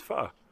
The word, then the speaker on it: pho: And so that may be some confusion, but yeah, it's pho.